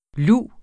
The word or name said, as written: luv